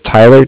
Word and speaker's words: Tyler: My name is Tyler, T-Y-L-E-R. – Okay. – And I'm calling from Bank of Cardiff. It's spelled C-A-R-D-I-F-F, as in Frank.